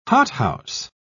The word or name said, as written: hothouse